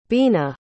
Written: bina

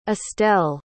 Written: estelle

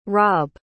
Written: robb

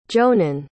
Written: jonan

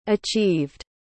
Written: achieved

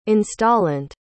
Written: installant

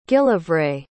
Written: gillivray

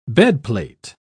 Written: bedplate

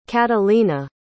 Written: katalina

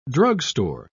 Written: drugstore

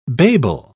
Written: babel

